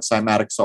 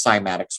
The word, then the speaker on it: cymatics: Well, what is cymatics? Well, first of all, it's spelled C-Y-M. So I'll always think of a symbol, like a drum symbol. Not a symbol that you draw, a symbol that you hit, and think of why a symbol is called a symbol.